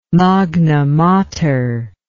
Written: Magna Mater

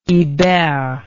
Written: ibert

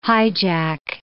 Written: hijack

